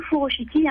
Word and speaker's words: furoshiki: Donc le furoshiki, hein, le U, on le prononce OU, c'est l'art japonais du pliage et du nouage du tissu.